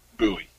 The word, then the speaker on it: Bowie: A lot of people mispronounce it as Bowie. I'm like, no, it's not. It's just nothing cool as Bowie over here. – You're right. Yeah, it's Bowie.